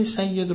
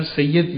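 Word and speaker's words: سیّد: کلمه سید رو همونی که ما به تلفظ زبانی خودمون راحت‌تر سید میگیم، یعنی به کسر میگیم، که درستش سید دیگه، میدونی دیگه، این فعل فیل.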